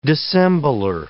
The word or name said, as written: dissembler